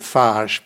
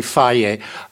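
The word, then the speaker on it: Fage: There was a huge fight between Chobani, the kind of the newcomer, and well, I call it Faj, but I don't know how you pronounce it in Greek. Probably Faye. – It is.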